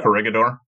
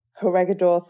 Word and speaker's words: Corregidor: Or Corregidor. – Corregidor, thank you.